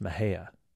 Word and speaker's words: Mexia: Stephen Becker, our producer, just whispered in my ear the town that's spelled M-E-X-I-A is pronounced Mahia here.